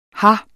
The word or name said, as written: her